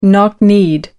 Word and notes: knock kneed: American English

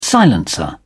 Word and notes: silencer: British English

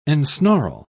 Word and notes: ensnarl: British English

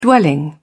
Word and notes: dwelling: British English

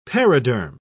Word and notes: periderm: British English

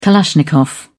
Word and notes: kalashnikov: British English

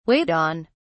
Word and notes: wait on: British English

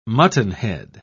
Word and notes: muttonhead: British English

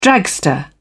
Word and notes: dragster: British English

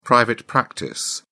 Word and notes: private practice: English; British English

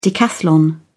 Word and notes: decathlon: British English

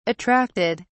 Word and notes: attracted: British English

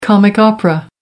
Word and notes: comic opera: American English

